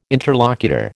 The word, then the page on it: interlocutor: American English